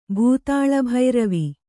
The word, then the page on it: ಭೂತಾಳಭೈರವಿ: Kannada